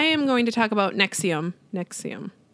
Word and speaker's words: NXIVM: So you guys, I had to ask Shiloh how you said this, because it's spelled N-X-I-V-M, which fuck these guys. Like if I didn't already hate them, the fact that you spelled it like this and then it's pronounced Nexium is like next level marketing bullshit that I hate.